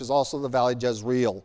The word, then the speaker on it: Jezreel: And you don't know much about Jezreel, probably, but Jezreel is probably the correct pronunciation.